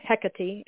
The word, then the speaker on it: Hecate: And then the goddess Hecate, or Hecate, as some people pronounce it, came into my world a few days ago, like demanding her novel.